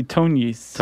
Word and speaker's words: Tognis: Do you know how to pronounce this one? T-O-G-N-I-S? – I would say Tonis. – Tonis, that's what I was going to say.